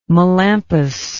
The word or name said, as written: melampus